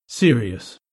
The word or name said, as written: serious